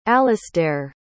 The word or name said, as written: alasdair